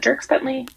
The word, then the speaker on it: Dierks Bentley: Yeah, and because I get his name wrong every time, is it Dierks Bentley? – It is.